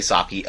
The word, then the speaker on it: sake: It's not sake. It's spelled S-A-K-E, so I can see people saying sake. – Yeah. – It's pronounced sake because there's an accent over that damn E.